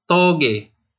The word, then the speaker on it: Toge: Is it Toge or Toj? – It's actually Toge. I kind of love like seeing other people mispronounce the name because it's kind of funny. – Because it's Indonesian words, right? – Yeah, it's Indonesian for bean sprout.